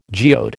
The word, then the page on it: geode: American English